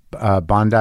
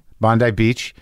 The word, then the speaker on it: Bondi: I went to Bondi, is that how you say it? – Yeah. – Bondi Beach.